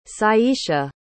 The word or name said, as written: Syesha